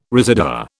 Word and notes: residua: British English

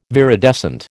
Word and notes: viridescent: American English

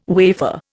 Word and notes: wafer: Indian

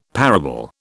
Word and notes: parable: British English

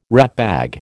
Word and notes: ratbag: American English

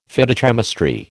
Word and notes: Phytochemistry: American English

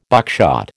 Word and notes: buckshot: American English

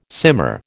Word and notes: simmer: American English

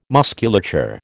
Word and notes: musculature: American English

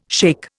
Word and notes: shake: Indian

